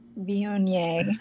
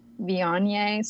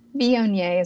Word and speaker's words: Viognier: So it is not Wagner or anything like that. It is Viognier. I've also had seen it as Viognier, so a different vowel, but Viognier is going to be a safe pronunciation and the correct one.